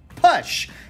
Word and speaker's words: push: A gente fez no IGTV um falando que é push, não push. E assim, por que que as pessoas falam push? – Eu imagino que seja por causa de palavras como much, palavras como fun, que elas têm a pronúncia da letra U mudada, chamando para o a. Então, quando a pessoa se depara com a palavra push, ela vai, oh, beleza, vamos naquela toada mesmo. Então, é push.